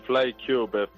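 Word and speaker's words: FlyKube: ¿FlyCube? – Sí, exactamente. FlyCube. Fly de volar en inglés y cube de cubo en inglés, pero con K.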